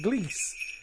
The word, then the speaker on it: Gliese: And then we got there was a thing called Gleese, which looked all right. It's spelled G-L-I-E-S-E, which is terrible spelling.